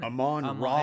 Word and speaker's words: Amon-Ra: Amon-Ra.